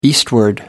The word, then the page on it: eastward: American English